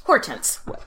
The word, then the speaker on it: Hortense: But now that she's in France, they say it with the French pronunciation, Hortense, which nice.